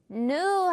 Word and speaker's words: new: 이렇게 새로운이라고 할 때 new 부분은 예전에 배웠듯이 너무 new 하지 말고요. u 발음으로 조금 더 편안하게 new 한번 가볼게요.